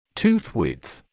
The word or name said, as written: tooth width